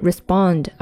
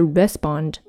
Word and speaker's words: respond: 这个单词它的重音是在第二个音节，所以是 respond 而不是 respond。